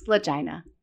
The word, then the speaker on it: Lagina: it has to be Lagina. I have to be pronounced right. No, it's like vagina. It's Lagina.